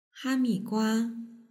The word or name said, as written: hamigua